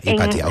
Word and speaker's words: Hypatia: Además, tu participación en la misión, ¿cómo se dice? Hypatia. – Hypatia, ok.